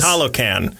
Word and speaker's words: Talokan: I don't remember how to pronounce it. Talokan? Sure. Sure is. Talokan.